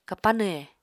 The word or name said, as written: kapanw he